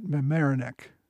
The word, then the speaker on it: Mamaroneck: Their next encounter with the British was, if I can pronounce it right, at Mamaroneck.